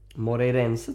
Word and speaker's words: Moreirense: Och vann nu i helgen mot, nu kommer jag uttala det totalt fel, Moreirense. – Moreirense tror jag. – Ja, tack så mycket.